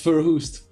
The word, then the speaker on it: Verhoest: Did I say that right, William? – Yeah, it's Verhoest.